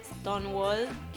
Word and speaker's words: Stonewall: Em, 28 de junio se celebra el Día Mundial del Orgullo debido a las conmemoraciones de los disturbios ocasionados en Stonewall, que es en Nueva York. Corregime si lo pronuncié bien. Lo dijiste bien.